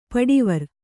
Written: ಪಡಿವರ್

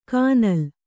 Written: ಕಾನಲ್

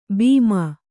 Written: ಭೀಮ

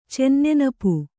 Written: ಚೆನ್ನೆನಪು

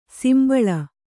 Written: ಸಂಭಾಳಿ

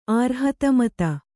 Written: ಆರ್ಹತಮತ

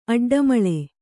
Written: ಅಡ್ಡಮಳೆ

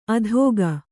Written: ಅಧೋಗ

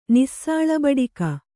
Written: ನಿಸ್ಸಾಳಬಡಿಕ

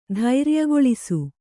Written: ಧೈರ್ಯಗೊಳಿಸು

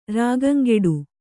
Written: ರಾಗಂಗೆಡು

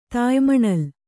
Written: ತಾಯ್ಮಣಲ್

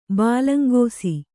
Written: ಬಾಲಂಗೋಸಿ

